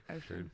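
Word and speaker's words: Oisín: O-I-S-I-N. Ocean. – Okay. I thought it was just like one of those hippie dad things. I'm naming you Ocean. – Well, that one I know is for sure pronounced like that, so I have no trouble with that one.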